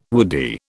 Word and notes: woody: British English

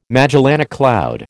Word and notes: Magellanic cloud: American English

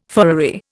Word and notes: furriery: Indian

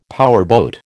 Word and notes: powerboat: American English

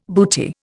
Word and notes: booty: Indian

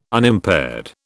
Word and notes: Unimpaired: British English

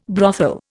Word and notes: brothel: Indian

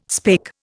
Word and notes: spake: Indian

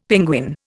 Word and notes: penguin: Indian

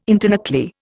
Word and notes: internuclear: Indian

